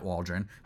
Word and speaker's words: Waldron: Maybe you get a little bit out of Matt Waldron.